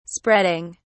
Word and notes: spreading: British English

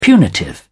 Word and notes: punitive: British English

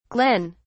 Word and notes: glenn: British English

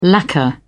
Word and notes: lacquer: British English

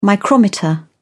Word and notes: micrometer: British English

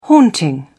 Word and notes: haunting: British English

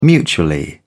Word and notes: mutually: British English

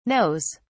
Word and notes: knows: British English

